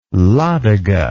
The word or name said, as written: Ladoga